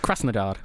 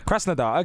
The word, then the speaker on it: Krasnodar: Krasnodar? – Krasnodar. – Krasnodar.